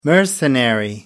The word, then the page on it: mercenary: American English